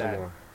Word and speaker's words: Voldemort: That's true, it's supposed to be everyone pronounced mispronounces Lord Voldemort. That, including me. I'm not going to start saying Voldemort, but apparently that's how Rowling does it. – Yeah, you know, it's a silent T.